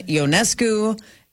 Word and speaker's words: Ionescu: Sabrina Ionescu, and yes, that is how you pronounce it, though that's not how it looks like it's pronounced.